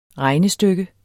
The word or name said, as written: regnestykke